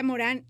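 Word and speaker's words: Morin: Edgar Morán, se escribe Edgar Morín, pero... se pronuncia Morán y es un autor francés que está por cumplir los 105 años, si no me equivoco.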